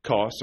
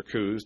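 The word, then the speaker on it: Coos: we came with a straight course unto Kos or Kuz, depending on how you want to pronounce it.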